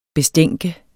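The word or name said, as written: BESTÆNKE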